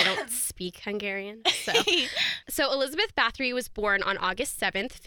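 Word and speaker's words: Báthory: And then Bathory is the correct way to say it.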